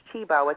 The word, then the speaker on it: Cibo: It's spelled C-I-B-O, but it's pronounced Chibo.